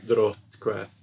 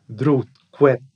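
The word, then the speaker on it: dróttkvætt: So Drottkvæð, with a reconstructed pronunciation. – Drottkvæð, something like that, yes.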